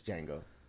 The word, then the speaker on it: Django: It's not the D is silent. It's Django.